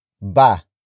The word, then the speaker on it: ba: The next word is ba. Ba. Ba. Ba is spelled as B-A.